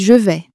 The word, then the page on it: je vais: french